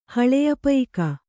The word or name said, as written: ಹಳೆಯಪಯಿಕ